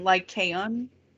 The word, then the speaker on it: Lycaon: Now, another legend is that of Lycon. I think I'm saying that right. – The Lycan or Lycon or... – Well, it's the person, Lycaon.